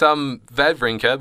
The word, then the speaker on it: Wawrinka: It's Wawrinka, because in German they pronounce W's as V's.